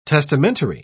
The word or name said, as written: testamentary